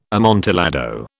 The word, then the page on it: amontillado: British English